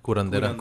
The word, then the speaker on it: curandera: And so, anyway, yeah, they took him to the one of those, you know, how do you say it? Curarera? – Curandera. – Curandera.